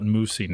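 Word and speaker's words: moussy: And so, the definition of what I pronounced as mousy, which I might retract and call it moosey now after reading this, but it's resembling or characteristic of mousse, especially in texture.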